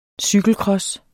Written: cykelcross

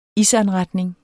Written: isanretning

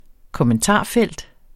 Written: kommentarfelt

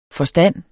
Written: forstand

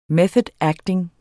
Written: method acting

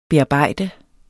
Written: bearbejde

